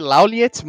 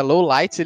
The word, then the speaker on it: Lawliet: Esse detetive que depois a gente descobre que o nome dele é, é Lowlight, se escreve L-A-W-L-I-E-T. Laul, se lê Lauliet, mas se, se pronuncia Lowlight, ele é o Sherlock Holmes japonês, né, cara?